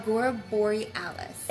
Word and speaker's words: Agora Borealis: So I hope y'all enjoyed y'all's tour of Angora Berlin. Am I pronouncing that right? – Agora borealis. – Agora borealis. It's almost like aurora borealis, but instead of aurora, it's agora.